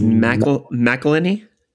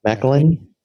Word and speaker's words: McElhenney: McElhenney. I don't know how to pronounce that very well. – I think it's McElhenney. – McElhenney. – That looks about right.